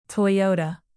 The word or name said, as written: toyota